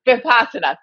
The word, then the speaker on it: Vipassana: And then there's the meditation that I like to practice, I always have a hard time pronouncing this, Vinyasa. Well, it's an open meditation where you sit quietly. – Vipassana. – Thank you. I always have a hard time pronouncing that.